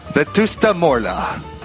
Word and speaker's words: Vetusta Morla: Vesta Morla? – Vetusta? – Vetusta Morla.